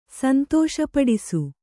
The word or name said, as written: ಸಂತೋಷಪಡಿಸು